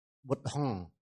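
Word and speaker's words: Buddhaṃ: Chữ M chấm dưới sẽ đọc là rung lỗ mũi. Bụt Hăng. Bụt Hăng. Nó rung lỗ mũi khi đó mà gọi là phát âm đúng.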